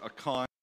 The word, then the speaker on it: Achan: We talked about Achan, or Achan, as it is pronounced in other places, and his sin, and as a result, the sin that caused Israel to fail in their battle against the city of Ai.